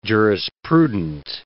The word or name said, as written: jurisprudent